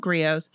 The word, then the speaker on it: griot: So, Griot pronounced griot.